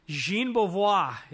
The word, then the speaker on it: Jean Beauvoir: Crown of Thorns is a band with John Beauvoir. Jean Beauvoir, if you want to pronounce it correctly.